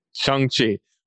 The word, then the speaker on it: Shang-Chi: Shang-Chi. – Well, because it was like Sean, like he's Sean. Name is Shang-Chi. – Yeah, Sean, Shang. – Shang, yeah, you're right. That's what it is, Shang-Chi.